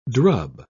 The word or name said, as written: drub